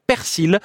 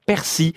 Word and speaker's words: persil: Ainsi, le mot persil est prononcé avec un L à la fin dans les trois quarts du pays, mais pas dans le centre de la France où il cède sa place au persil.